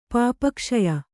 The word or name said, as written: ಪಾಪಕ್ಷಯ